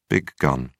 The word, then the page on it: big gun: American English